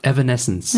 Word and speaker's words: Evanescence: Was übrigens die richtige Aussprache ist, ich habe es mir von äh, Wikipedia vorlesen lassen nochmal. Sag's nochmal. – Evanescence.